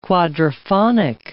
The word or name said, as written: quadraphonic